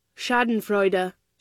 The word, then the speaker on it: Schadenfreude: Schadenfreude. – Here it is. – Schadenfreude. – Oh, you missed the D.